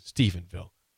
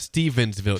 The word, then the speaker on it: Stephenville: Sip is pronouncing it correctly on Stephenville." – Stephensville. – You guys said S though. It's not Stephensville. It's Stephenville, and it's S-T-E-P-H.